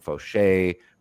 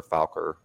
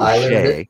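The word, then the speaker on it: Faucher: Another is Anthony Bednar of Miami, who at this point with Tinoco on IL, Calvin Fauché, who I learned is the correct pronunciation. I have been butchering it, calling him Falker, you know. – Well, Fauché.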